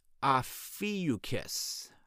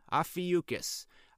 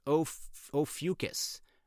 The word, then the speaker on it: Ophiuchus: It's pronounced Ophiuchus. Ophiuchus. I've also heard it pronounced Ophiuchus. It's hard to pronounce.